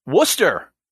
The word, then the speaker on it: Worcester: That is how it's pronounced, and that, that took me aback when I first moved up here. Not like the sauce, right? This Worcester. – Worcester, like W O O S T E R.